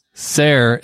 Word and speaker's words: Ser: Ser is pronounced exactly the same as sir. Martin uses the sir, S-E-R spelling from Old English. He spelled that with an O-L-D-E, by the way, from Old English, but it's pronounced the same.